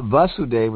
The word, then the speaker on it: Vasudeva: But Vasudev with a short A is the father of Krishna, And Vasudeva with a long A is Krishna.